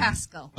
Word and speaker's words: Pascal: Pascal. – Okay, Dr. Pascal? – Pascal, like Pascal from Hot Springs, Arkansas.